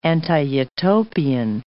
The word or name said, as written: anti-utopian